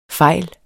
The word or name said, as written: fejl